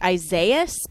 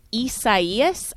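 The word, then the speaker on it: Isaías: So Tim, yesterday we were talking about this, and it looked like the name, which I was thinking pronounced Isaias, because it looked like Isaiah, and I was partly right that it does come from the biblical Hebrew name Isaiah, but it's the Spanish and Portuguese, and so it's actually pronounced Isaias.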